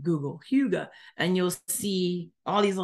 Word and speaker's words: hygge: If you see it, it's HYGGE. IKEA even has a mat that says it, and it's pronounced puga.